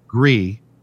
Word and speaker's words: Gris: Gris in French. I don't think you pronounce the S.